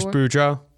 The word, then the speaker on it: Boudreau: Evanston was the replacement for the last Wild coach, Bruce Brodeur. – Bruce Boudreau.